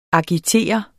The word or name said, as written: agitere